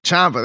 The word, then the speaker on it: Chxxmpa: Chxxmpa, there you go.